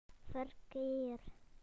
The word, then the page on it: traffic: British English